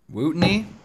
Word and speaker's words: Wuttunee: I think I called her Rootney. I don't think that's how you say it.